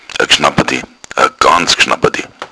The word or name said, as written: gschnappat